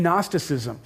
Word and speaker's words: Gnosticism: Nice word, starts with a silent G, Gnosticism. And it's a belief that all matter is evil.